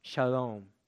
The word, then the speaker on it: shalom: actually pronounced shalom.